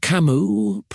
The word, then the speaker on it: Camus: Albert Camus, pronounced Albert Camus, was a philosopher born in Algeria in 1913.